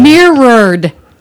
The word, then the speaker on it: mirrored: Mirrored. – It's two syllables.